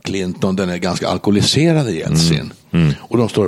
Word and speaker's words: Jeltsin: Boris Jeltsin. Yes. Han ska uttalas på det. Så jag har nämligen läst ryska en gång i tiden så jag försöker använda det. Jättesnyggt ryska uttal. Ja, Boris Jeltsin.